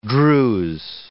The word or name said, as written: Druse